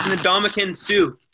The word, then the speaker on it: Ndamukong Suh: Hey, Ndamukong. Nope. It's Ndamukong Suh.